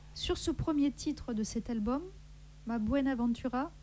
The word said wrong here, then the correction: Mi Buenaventura: je l'ai bien prononcé, vas-y. – Mi Buenaventura.